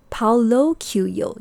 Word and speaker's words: Paulo Coelho: Paulo Coelho, yes.